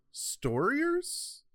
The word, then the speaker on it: Starriors: This is 1984's Starriors by Marvel. – Yeah. The thing is, there's a case to be made that it should be pronounced Storiers. Because Warriors.